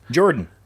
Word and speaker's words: Jordynne: Jordan. – Yes. – Jordynne Grace. – I pronounced that properly.